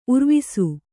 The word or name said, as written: ಉರ್ವಿಸು